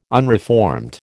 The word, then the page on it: unreformed: American English